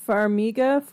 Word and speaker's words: Farmiga: Vera Farmiga.